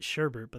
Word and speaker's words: sherbet: It's like everybody calls it sherbet, but there's no R in there, so... People mispronounce it.